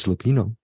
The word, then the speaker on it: Lupino: Is it Lupino or Lupina? It's Lupino. Let's just say Lupino.